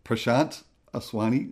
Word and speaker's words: Prashant Aswani: So the other people you did mention before with Prashant Aswani, is that how you say his name? – Yeah. – Yeah. – Yes.